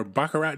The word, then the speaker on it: Baccarat: Slower than a 21 dealer or a Baccarat dealer? Because there's no clocks in those places. – Okay, first of all, if you're playing Baccarat, which I don't think you pronounce the T at the end, Baccarat.